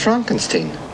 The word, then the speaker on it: Frankenstein: No, it's pronounced Frankenstein.